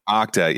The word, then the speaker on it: Okta: They're breaking all uh, they they went after Okata. How, how do you say that? – Uh, Okta. Yeah, I always mess that up.